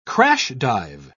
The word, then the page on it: crash-dive: British English